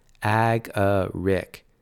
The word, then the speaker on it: agaric: Tanner said, pronouncing the word slowly. "Ag-a-rick.